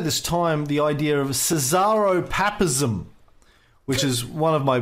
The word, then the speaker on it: Caesaropapism: Cesaropapism.